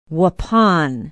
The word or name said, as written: waupun